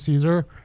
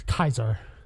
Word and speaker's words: Caesar: Yeah, it's Kaiser. If they pronounce it Caesar, yeah.